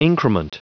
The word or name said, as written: increment